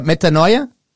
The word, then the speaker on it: metanoia: Is that metanoia? – Well, it's And that's the one.